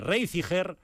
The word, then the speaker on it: Reiziger: non sei se vos acordáis de Reiziger, pois eso era con G de Geada, que é como pronuncian ali.